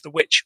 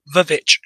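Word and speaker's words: VVitch: There's people out there going, I think you'll find it's pronounced The Witch. It's spelt Vavitch, so it's Vavitch. If Mark Kermode called it Vavitch, then so do I.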